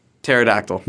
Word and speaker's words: pterodactyl: It starts with a P. – Pterodactyl starts with a P. – Yeah, the P is silent.